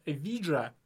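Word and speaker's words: Evija: И есть еще Эвиджа. Ну, очень сложное название. Эви... Пишется Евиджия.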